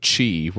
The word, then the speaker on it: Qi: It also has a, he said qi, which I know is how you say it in the traditional Chinese. Mm-hmm. But I always say qi charging because it's Americanized. It's anglicized.